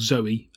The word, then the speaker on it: Zowie: So this is the battle with the main antagonist in the game called Zoe, written Zowie but pronounced Zoe, like Zoe Bowie.